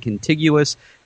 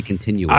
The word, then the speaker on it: contiguous: contiguous. Did I say that right? I always say continuous.